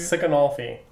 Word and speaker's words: Siconolfi: Siconolfi.